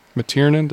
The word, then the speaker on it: McTiernan: McTiernan, did I say that right? – Mm-hmm.